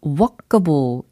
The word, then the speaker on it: walkable: workable이 아니고 walkable 이었습니다. W-A-L-K A-B-L-E.